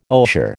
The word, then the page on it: osier: American English